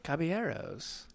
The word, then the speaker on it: caballeros: Caballeros. – Oh my gosh, neither one of you can say. – Okay. How do you say it? – Caballeros. There you go.